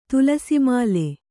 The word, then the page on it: ತುಲಸಿಮಾಲೆ: tulasi māle